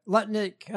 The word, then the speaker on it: Lutnick: Lutnick, I said it right.